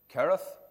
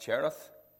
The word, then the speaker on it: Cherith: Remember, he went to the brook Kereth. That's how Mr. Douglas taught us to say it, not Cherith. The CHs of the Bible are all pronounced as a K, except for the name of Rachel.